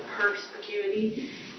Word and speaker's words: perspicuity: Okay, so in your, how do you pronounce that? – Perspicuity.